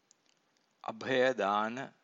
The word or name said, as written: abhaya dāna